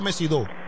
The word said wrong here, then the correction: Mesido: no, Mesidó no. Eduardo Mesido.